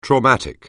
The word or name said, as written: traumatic